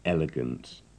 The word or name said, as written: elegant